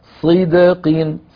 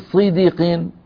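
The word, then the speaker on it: صِدْق: وجعلنا لهم لسان صدق، الآية 50 صيدقين، صيدقين، الدال ساكنة مقلقلة قبلها كسرة بعدها كسرة، علينا أن ننطقها مقلقلة غير متأثرة لا بالكسرة قبلها ولا بالكسرة بعدها. وجعلنا لهم لسان صدق عليا، صدق صدق، تبهنا، ولا نقول صديق صديق، لا نجعل الدال كأنها مكسورة.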